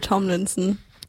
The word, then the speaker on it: Tomlinson: Jetzt haben wir für euch Sunflowers von Louis Tom Linsner. Linsorn? Wie wird es ausgesprochen? Kann mir jemand helfen? – Sunflowers von Louis Tomlinson.